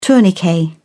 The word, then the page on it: tourniquet: British English